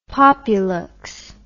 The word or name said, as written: populuxe